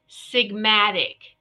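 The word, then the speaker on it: Four Sigmatic: For, it's spelled out F-O-U-R. Sigmatic, SIGMATIC, for somatic.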